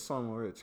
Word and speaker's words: St. Moritz: It's St. Moritz. Because, like, they speak French in the area.